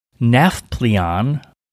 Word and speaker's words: Nafplion: Starting with the first one, which is Nafplion, N-A-F-P-L-I-O-N, Nafplion.